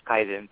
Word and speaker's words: Kaizen: With all due respect, it's pronounced Kaizen, Bob, so just so you know, and...